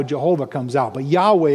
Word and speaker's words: Yahweh: In the Old Testament, when you see that word Lord, it's that Hebrew word, we're not exactly sure how to pronounce it, but they did say it at one time, Yahweh. King James had it as Jehovah, but they never actually said it that way.